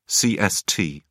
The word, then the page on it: cst: British English